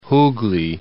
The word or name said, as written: Hooghly